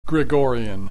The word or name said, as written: Gregorian